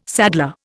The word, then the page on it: saddler: Indian